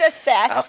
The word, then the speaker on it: assess: So, our weekly winning word is assess. – I'm glad you pronounced that for me. I thought it was asses. – Kurt, your mother is in the audience. – Oh, sorry, Watch Mom. – That's hilarious. Actually, it is assess.